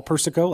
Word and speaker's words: Persico: Persico, like Serpico? – Like Serpico, yeah.